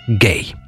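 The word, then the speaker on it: Gaye: Хотя, опять же, даже с буквой «и» фамилия все равно произносится как «гей».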